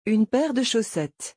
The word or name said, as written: une paire de chaussettes